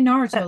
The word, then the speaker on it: Naruto: Say Naruto, that's how you say it.